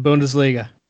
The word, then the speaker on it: Bundesliga: Is Bush, correct me if I'm wrong on the pronunciation, Bundesliga? – You are correct. The Bundesliga